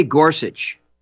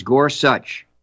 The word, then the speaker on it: Gorsuch: He doesn't say Gorsuch. He says Gorsuch. So if you want to pronounce, I always say pronounce a man's name or a woman the way they pronounce it. And Gorsuch is exactly how he says his own name.